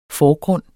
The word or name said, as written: forgrund